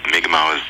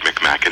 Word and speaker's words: Mi'kmaq: I think Mi'kmaq is the probably the old ancient pronunciation that people are trying to get back to. But yeah. I grew up with the incorrect Mi'kmaq.